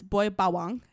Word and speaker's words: Boy Bawang: It's the brand is Boy Bawang is how you pronounce it.